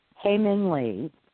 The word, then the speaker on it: Hae Min Lee: Hae Min Lee. – Hae Min Lee.